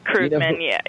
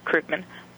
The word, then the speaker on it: Krugman: And I believe his name is, I'm probably not going to say it right, but it looks like Krugman, Paul Krugman. I think he pronounced it differently. – It's Krugman, that's how he pronounces Krugman.